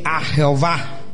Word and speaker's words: Jehová: Pero nuestra traducción, Reina Valera, usa este, J-E-H-O-V-A, Jehová.